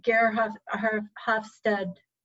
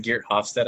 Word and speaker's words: Geert Hofstede: So how do you see the scale, the Gerhard Hofstede, how do you say it? – It's like a Dutch name or something. It's like Geert Hofstede, I think it's how it's pronounced.